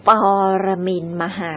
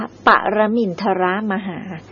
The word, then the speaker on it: ปรมินทรมหา: คืออ่านว่าปรมินมหาไม่ใช่อ่านว่าปรมินทรมหา